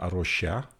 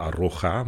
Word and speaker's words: A Rocha: Ik heb net geleerd van haar hoe je het moet uitspreken, dus dat is heel fijn. Wij zeggen thuis altijd Arocha, maar dat is dus eh, de Hollandse lompenbenadering, zullen we maar zeggen. Leuk dat we hier kunnen zijn om ja, te praten over duurzaamheid en over je werk.